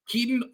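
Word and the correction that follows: Kitan: Oladapo? – Kaitan, I believe.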